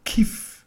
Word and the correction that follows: Kvi: Also K-I oder. Nee, Kvi. K-V-I. – Nochmal, wie schreibt man es? – K-I oder K-V-I.